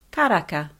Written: karaka

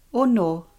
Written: ono

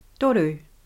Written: toru

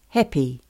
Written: koa

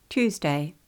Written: Tuesday

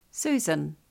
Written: Susan

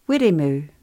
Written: wirimu

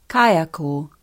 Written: kaiako

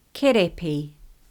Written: Kerepi

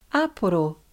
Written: Aporo

